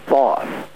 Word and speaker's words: Thoth: That's an interesting question, Howard, because in Egyptian legend and mythology, the god of wisdom, Thoth, or Tahoti as he's pronounced in some cultures, comes from the moon.